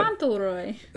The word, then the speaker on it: Fauntleroy: Fauntleroy.